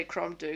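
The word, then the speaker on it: Crom Dubh: but D-U-B-H is pronounced do in Scottish, as in ski and do. So I'm just going to say crom do, because whatever.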